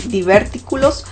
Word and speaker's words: divertículos: divertículos. ¿Sí se pronuncia así? Divertículos, ¿verdad? Así se pronuncia, ya le estaba quitando el acento.